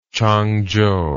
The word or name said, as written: Changzhou